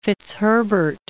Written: Fitzherbert

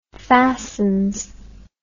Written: fastens

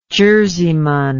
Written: jerseyman

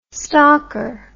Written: stalker

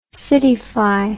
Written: cityfy

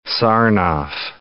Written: Sarnoff

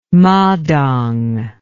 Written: Madang

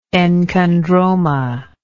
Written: enchondroma

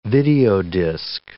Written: videodisc

